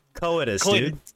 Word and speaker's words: coitus: Koi. Like a koi pond, and then tis. – Coitus, dude.